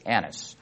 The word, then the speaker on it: Annas: They lead him away to Annas first. You got to be very careful. That's a short A, not a long A, Annas.